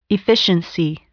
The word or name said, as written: efficiency